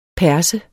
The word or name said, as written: perse